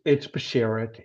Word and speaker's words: Bashert: Am I saying it right? – It's Bashert. The word is in Yiddish.